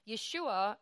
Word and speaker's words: Yeshua: So Yeshua is the original pronunciation in Aramaic, which was the language that they spoke in the time of Yeshua and before and after.